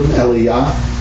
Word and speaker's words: Eliyah: So we have here, to keep them a little bit easier instead of Elijah and Elisha, I find it easier to pronounce it in Hebrew, Eliyah and Elisha.